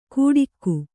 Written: ಗೂಡಿಕ್ಕು

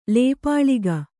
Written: ಲೇಪಾಳಿಗ

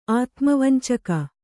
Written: ಆತುಮವಂಚಕ

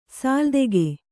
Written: ಸಾಲ್ದೆಗೆ

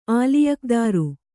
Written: ಆಲಿಯಕ್ದಾರು

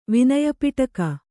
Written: ವಿನಯಪಿಟಕ

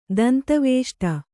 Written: ದಂತವೇಷ್ಟ

